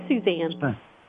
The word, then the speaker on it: Suzanne: Is it Suzanne or Susan? – It's Suzanne.